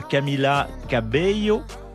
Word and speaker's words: Cabello: Je parle de Camila Cabello. J'essaie de bien le prononcer. – Bel accent. – Puisque les deux L se transforment en yo, on ne va pas faire un cours.